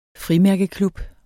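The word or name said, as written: FRIMÆRKEKLUB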